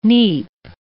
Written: neep

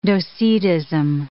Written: Docetism

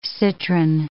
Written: citron

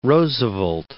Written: Roosevelt

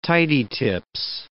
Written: tidy tips

